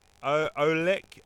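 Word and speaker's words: oleic: Olek.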